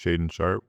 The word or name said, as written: Shaedon Sharpe